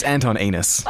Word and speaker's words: Anton Enos: We've actually had somebody come in, and forgive my bad pronunciation if this is wrong, Anton Unessa? Is that? I don't know if that's correct. – Michelle, it's Anton Enos.